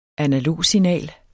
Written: analogsignal

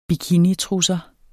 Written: bikinitrusser

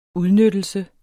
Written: udnyttelse

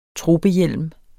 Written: tropehjelm

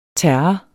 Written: terre

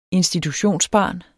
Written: institutionsbarn

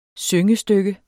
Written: syngestykke

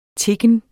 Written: tikken

